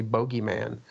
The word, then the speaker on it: bogeyman: I always wondered why Americans say boogeyman, though. Here in the UK, people always say bogeyman.